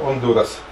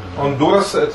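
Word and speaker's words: Honduras: Там повинно в огуле быть «ондурас». Гондурас, там навод натиск неправильно, и не «г», а там же «аш».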